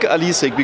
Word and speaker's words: Alesic: I think Alesic, because it's like the smaller form of Alisa. And a small Alisa is like Alesic in Russian.